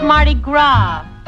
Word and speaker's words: Mardi Gras: Jimmy, that's Mardi Gras. The S is pronounced ah, as in pâté de foie gras, Vichy soir.